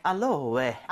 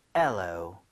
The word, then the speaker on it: aloe: 알로에가 알로에. – 아니라 발음은 엘로입니다. – 뭐요? – N은 아예 발음이 없고요. 엘로.